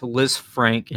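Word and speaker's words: Lisfranc: So I just want to say real quick, it's Lanfranc injury. You said Lanfranc or something like that. You said it wrong.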